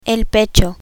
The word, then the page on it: pecho: Spanish